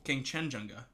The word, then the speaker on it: Kangchenjunga: Yeah, and the next one is Kangchenjunga. – Careful. – Kangchenjunga.